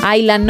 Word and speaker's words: island: En Island no pronuncian la S.